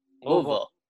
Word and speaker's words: Louisville: Louisville. It looks weird, but it's the way we say it.